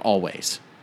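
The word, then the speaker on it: Alvvays: this band called Always. It's actually also, like Churches, spelled with two V's instead of a W.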